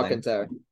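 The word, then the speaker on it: Alcántara: Sandy Alcon, Alcon, I can never say his name. – Alcantara, There we go.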